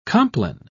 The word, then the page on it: compline: British English